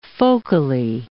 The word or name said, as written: focally